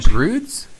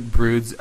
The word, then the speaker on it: Broods: What was there was a Broads video this week that was super cool. – Isn't it Broods? – I think so. – That's right, Broods. Laugh, L-A-F.